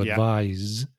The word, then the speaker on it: advise: So when it's a verb, the S is pronounced like a Z sound. So to advise.